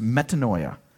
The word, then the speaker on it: metanoia: The New Testament word in the Greek is pronounced metanoia, and it's a compound word.